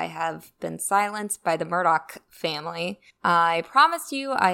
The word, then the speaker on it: Murdaugh: So first of all, the name is pronounced Murdoch, not Murdaw. If you hear a reporter saying the name without the k at the end, they're basically admitting to having no sources in Hampton County and not knowing what they're talking about.